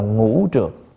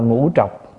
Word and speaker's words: ngũ trược: Vì có đầy đủ năm thứ nhiễm ô, chúng ta gọi là trược, gọi là ngũ trược. Người Bắc đọc là ngũ trọc.